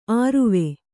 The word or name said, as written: ಅರುವೆ